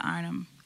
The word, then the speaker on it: Arnhem: You know, thanks for bringing me to Arnhem.